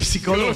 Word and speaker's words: psicólogo: Y psicólogo. – Pero la P no suena. El pip psicólogo. No suena.